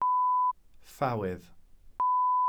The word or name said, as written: ffawydd